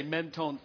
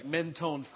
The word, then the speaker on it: Menton: He went down frequently to Mentone, France. It's actually pronounced Menton, I understand. One of our French missionaries told us that, but it looks like Mentone, France.